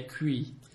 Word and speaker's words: couilles: Is a nice slang term is le cui. – Le cui? – No, it's like V is le, and then cui. – Cui. – It's spelled C-O-U-I-L-L-E-S. Cui. Cui.